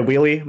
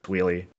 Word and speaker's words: WheelyF: I go by Wheelie. My real name's Evan. F is silent. You can say it, but I usually self-refer as just Wheelie.